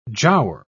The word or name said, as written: giaour